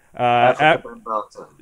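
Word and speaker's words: Afrika Bambaataa: You know, you sent me an article to read on how do you pronounce his name? Let's see here. – Africa Bambaataa. – Yeah, Africa Bambaataa, and which goes into all of this history.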